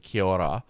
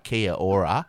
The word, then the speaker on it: Kia ora: started the episode saying Kia ora, and I was like, oh shit, I, I'd never, whenever I'd seen that, that word, I'd always, you say it like an Australian where it's like Kia ora.